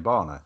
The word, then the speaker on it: Bana: Is that how you pronounce his surname, Barner? Just out of interest.